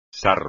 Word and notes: sarro: espagnol

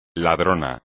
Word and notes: ladrón: espagnol

